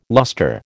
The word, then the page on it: luster: American English